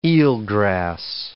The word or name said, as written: eelgrass